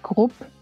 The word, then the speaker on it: Grupp: Yeah, in like German pronounced, it's Grupp. It's like group, but the German way. – So it's with a short U, so Miriam Grupp? – Yes.